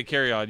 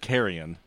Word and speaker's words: Carrion: I say Carry On, you say. – The correct pronunciation was just Carrion.